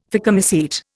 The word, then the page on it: phycomycete: Indian